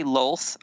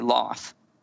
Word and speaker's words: Lolth: I say Lolth. I used to say Loth. – I used to say Loth, but now I say Lolth because we have Lol, L-O-L. That's why.